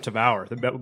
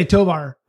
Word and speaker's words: Tavour: I've been pronouncing that wrong this whole time. Tavour? – I'm not educated on this. I always say Tovar.